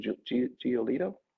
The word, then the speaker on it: Giolito: So those two guys, Cole and Giolito? – Right.